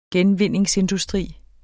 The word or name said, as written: genvindingsindustri